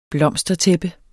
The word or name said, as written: blomstertæppe